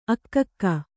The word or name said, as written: ಆಗ್ಗೆ